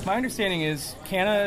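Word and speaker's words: Canha: Yeah, it's a Portuguese name, so technically in Portuguese you would pronounce it Kanya. – Sure. – I'm like third or fourth, probably fourth generation American at this point, so I don't really... It's been Canna my whole life.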